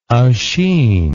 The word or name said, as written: oisin